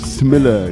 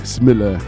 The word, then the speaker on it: Smille: My name is Smille. You say it like Italian, mille, una mille. You only put the S in the front, Smille.